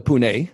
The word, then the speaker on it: Pune: And you also went to school in Pune, if I said that right.